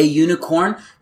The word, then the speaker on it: unicorn: A unicorn, because you don't pronounce it with a U, you pronounce it with a Y.